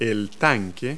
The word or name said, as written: tank